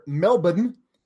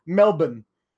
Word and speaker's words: Melbourne: See you in Melbourne, or as they say down under, Melbourne. And as Tom Phillips insists on calling it Melbourne, like, okay, yes, I understand. That is how they pronounce it. But you don't pronounce it that way, Tom. You pronounce it Melbourne.